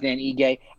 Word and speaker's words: Ige: Ige. Yeah. Versus Dan Ige.